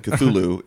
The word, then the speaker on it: Cthulhu: The one that we are using, Cthulhu, yeah. is sort of the standard, it's like sort of the Protestant version nice.